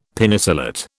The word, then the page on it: Penicillate: British English